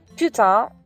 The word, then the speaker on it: putain: Ha ah, una piccola curiosità fonetica riguardo la parola putain Dunque, putain, come dicevo prima, si scrive putain, P-U-T-A-I-N, e la parte finale, T-A-I-N, si pronuncia con questo suono che sembra una A molto nasale, quindi tin, putain.